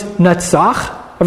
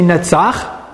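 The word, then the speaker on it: Netzach (נצח): The Hebrew verb for overcoming is Netzach. Everybody say Netzach. It's spelled Nun Tzadi Chet. Netzach. If you want to say an overcomer, you say Menatzeach.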